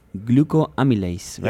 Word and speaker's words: glucoamylase: I really like your study in which you evaluate the use of glucoamylase, right? – Yep. – Is that how you say it? – Glucoamylase. Exactly, that's correct.